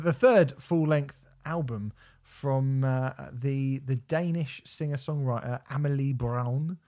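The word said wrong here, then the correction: Bruun: Brun? – Yeah.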